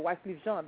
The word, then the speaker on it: Wyclef Jean: So it's just kind of people with Wyclef Jean, or Wyclef Jean, that's how you pronounce it, who've been there before, now, and will be again afterwards.